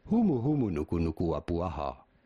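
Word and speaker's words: Humu humu nuku nuku apuaha (humuhumunukunukuapuaʻa): How do you go about pronouncing this long word? Humu humu nuku nuku apuaha. That was pretty good.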